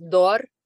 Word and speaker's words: dor: Yeah, there is one word that is called, that's pronounced dor, spelled D-O-R,